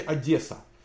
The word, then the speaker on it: Одесса: Михаил, вы очень правильно говорите Одесса через Е, а не как 99% людей через Э.